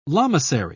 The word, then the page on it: lamasery: British English